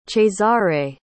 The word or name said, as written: Cesare